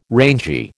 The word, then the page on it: rangy: American English